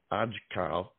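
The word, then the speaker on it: Aajkal: of the song is pronounced Ajkal.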